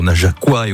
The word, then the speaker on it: Najaquois: Depuis 20h, on s'est pas mal baladé, même si on a offert un joli coup de projecteur au Najaquois et au Najaquoise, bien comme ça qu'on prononce.